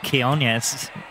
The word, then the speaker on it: Quiñones: He's got big Quiñones. Is that how you say it? – Yes.